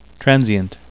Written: transient

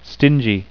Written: stingy

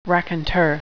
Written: raconteur

